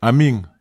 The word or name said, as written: amig